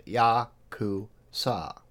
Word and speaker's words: Yakuza: Our understanding is 8-9-3 is the worst possible hand, and phonetically it is pronounced Ya-ku-sa.